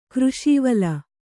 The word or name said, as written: ಕೃಷೀವಲ